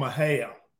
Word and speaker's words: Mexia: I grew up in a little town called Mexia, M-E-X-I-A.